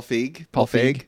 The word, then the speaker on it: Feig: Paul Feig? How do you say his name? – Paul Feig.